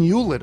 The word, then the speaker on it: Hewlett: I didn't immediately make the connection because you never pronounced the H. Because it's Hewlett. Well, how am I supposed to pronounce it?